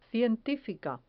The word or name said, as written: cientifica